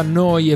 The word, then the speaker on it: Neu: Si scrive Neu, si pronuncia Noi e vuol dire nuova, nuova emittente urbana.